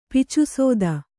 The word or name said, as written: ಪಿಚುಸೋದ